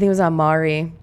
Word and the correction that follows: Maury: Is that how you say it? – Maury. – Yeah. Well, aunt, same thing, right? On Maury, Charles. So you know how they always have like the cheating. Oh, it is Maury.